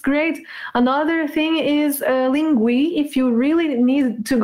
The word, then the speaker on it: Linguee: So I would recommend Lingui with double E in the end, Lingui.